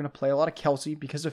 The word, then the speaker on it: Kelce: Or Travis Kelce, I guess, is we've been saying it wrong. Travis, I'm sorry. Travis Kelce.